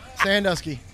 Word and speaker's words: Sandusky: Sandusky. Yes, sir. – Like Ohio. – That's it.